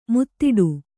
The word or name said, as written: ಮುದುಡು